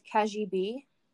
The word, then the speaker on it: Cagibi: It's called Cagib. It's a French word. It's spelled C-A-G-I-B-I.